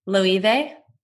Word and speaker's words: Loewe: L-O-E-W-E. Loewe? – Westman? – No, Loewe. For candles. L-O-E-V...